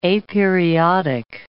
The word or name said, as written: aperiodic